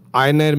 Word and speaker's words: Aigner: Einer Munich. – Is it Einer or what? – A-I-G-N-E-R. So, look, G ko kabhi G bolne ka hi nahi idhar.